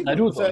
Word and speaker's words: Naruto: Pamela Giles con su baile Naratu, no, lo dije mal. – No, sí, Naruto, Naruto.